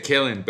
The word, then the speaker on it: Kalen: El año pasado me estabas preguntando cómo se decía su nombre, Se dice Kellen, pero se escribe Kalen.